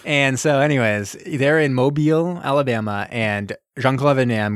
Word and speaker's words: Mobile: And we're in Mobile, right? Mobile? – Yeah, Mobile, Alabama. – I didn't want to say it wrong. I didn't want to say it like mobile, like an idiot. It's Mobile, Alabama.